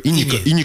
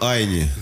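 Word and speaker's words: Ini: мне как-то вот почему-то претит называть его Айни, я его постоянно про себя, ну, как он пишется, так и говорю. Ини Камози, да. – А может, он так и пишется, Ини Камози? – Нет, на самом деле он, к сожалению, Айни.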